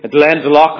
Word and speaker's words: Glendalough: Many people mispronounce that, but it's truly Glendalough. That's the proper pronunciation.